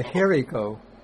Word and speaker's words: hiri: A hiriko. Okay, a hiriko is based on the noun hiri. Hiri ending with a long I.